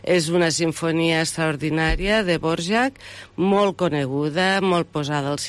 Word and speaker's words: Dvořák: Dvořák. – Perquè jo fins que aquí no vaig aprendre com es pronunciava sempre el Dvořák. Sí, no, Dvořák.